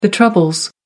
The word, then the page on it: troubles: American English